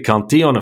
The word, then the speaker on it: Cantillon: It's called I'm in France, I should use the French pronunciation, I suppose, the Cantillon effect, which is, you know, those that are closest to the spigot of money will benefit the greatest.